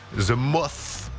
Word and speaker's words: The Moth: Avec son nouvel album qui s'appelle The Moth. Ça se prononce comme ça, The Moth. The Moth. – Non, c'est bien, The Moth. – Oh, the Moth. – Le TH est parfaitement prononcé, bravo.